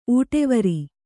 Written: ಊಟೆವರಿ

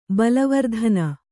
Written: ಬಲವರ್ಧನ

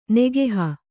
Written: ನೆಗಹು

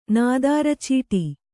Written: ನಾದಾರಚೀಟಿ

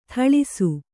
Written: ಥಳಿಸು

